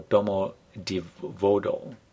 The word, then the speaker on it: Domodedovo: There's the Domo, or Domodedovo, D-O-M-O-D-E-D-O-V-O, and there's Sheremetyevo, S-H-E-R-E-M-E-T-Y-E-V-O.